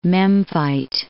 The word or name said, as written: Memphite